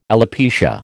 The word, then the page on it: alopecia: American English